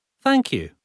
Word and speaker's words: thank you: Vārdā fine dzirdam F, taču vārdā thank you ir šļupstošs F, nevis F. Vēlāk šajā nodarbībā vēlreiz apgūsim šīs skaņas izrunu F.